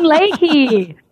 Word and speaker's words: Leahy: And I always say, Orale Hee Hoo, Susan Leahy.